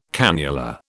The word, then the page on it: cannula: British English